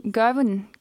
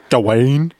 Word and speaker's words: Gawain: Garwin, ja. tror jeg. Garwin. Garwin. – Ja, for det, det er ikke Gawain.